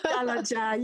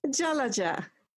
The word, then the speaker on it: Jalaja: It's Jalaja. Yes, it's a very hard name. – Oh, say that again. It's beautiful when you say it. – Jalaja.